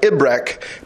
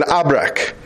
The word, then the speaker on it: Ibrek: Although in modern Arabic, it's pronounced more like Ibrek than Abrek.